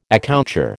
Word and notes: accoucheur: American English

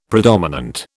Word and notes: predominant: British English

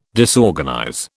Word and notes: disorganize: British English

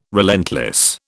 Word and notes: relentless: British English

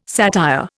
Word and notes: satire: Indian

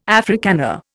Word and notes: Africana: Indian

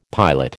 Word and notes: pilot: American English

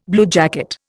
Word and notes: bluejacket: Indian

